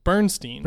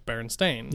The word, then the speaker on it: Berenstain: But what shook me more than that was I thought it was BERN. Not BERN. Like, I always pronounce it in my head or remembered Berenstain. – It as Bernstein. – Bernstein. Not the Berenstain. – Not Berenstain. – Yeah, or Stain. Berenstain. – Apparently it's Berenstain.